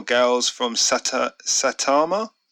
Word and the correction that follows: Saitama: I think it's Satama. Saitama. – Osatama. – No, Saitama.